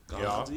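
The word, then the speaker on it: Gaarden: Oder spricht man das aus? – Garden? Ja, Garden ist ein Stadtteil von Kiel.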